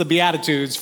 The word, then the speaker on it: Beatitudes: But we're in the South, and so we're going to stretch out them syllables, and it's the Beatitudes for the course of this series.